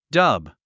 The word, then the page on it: dub: dʌ́b; ダブ